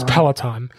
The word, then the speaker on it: Peloton: He kept mispronouncing it. I didn't want to correct him. It was hilarious. Peloton. But it's Peloton.